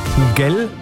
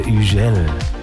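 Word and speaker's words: Hugel: Die Franzosen, die sprechen das H ja nicht, ne? Also Miguel heißt der DJ, wenn ich es deutsch aussprechen würde. Aber weil er Franzose ist, heißt er Ugel.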